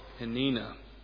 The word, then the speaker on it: Peninnah: I think that's pronunciation. Peninnah. If you look at the Hebrew, it's as close as I can get to it.